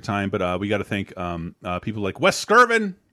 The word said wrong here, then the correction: Wes Craven: I'm saying his name right. Wes Sekervin. Sickervin. – Wes Craven. Thank you, Wes.